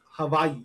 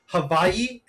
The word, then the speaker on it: Hawaii: But one of the places, like, is Hawaii. You know, you're supposed to pronounce it Hawaii and not Hawaii.